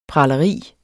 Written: pralerier

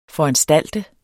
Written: foranstalte

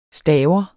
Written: staver

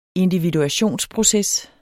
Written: individuationsproces

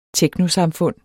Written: teknosamfund

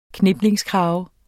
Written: kniplingskrave

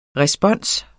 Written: respons

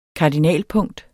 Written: kardinalpunkt

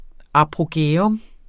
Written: apogæum

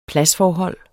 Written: pladsforhold

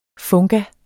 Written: funga

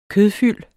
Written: kødfyld

